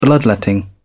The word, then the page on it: bloodletting: British English